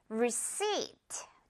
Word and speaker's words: receipt: 중요한 단어, 영수증, receipt. 뒷부분의 P는 그냥 없다고 생각하면 됩니다.